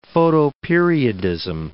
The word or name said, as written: photoperiodism